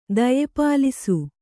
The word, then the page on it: ದಯೆಪಾಲಿಸು: Kannada